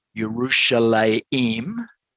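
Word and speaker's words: Yerushalayim (ירושלים): The place name Jerusalem, pronounced Yerushalayim in Hebrew, is a combination of two words.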